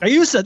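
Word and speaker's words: Guayusa: Gayusa, that's it. God damn it. Why do I say that wrong? Gayusa.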